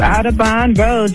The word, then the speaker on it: Audubon: I'm talking about 7801 Audubon Road. Am I saying that right? – Yes, online. Audubon Road. You got it.